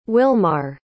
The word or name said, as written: Willmar